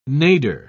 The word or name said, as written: nadir